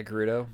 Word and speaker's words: Gerudo: Yeah, Gerudo.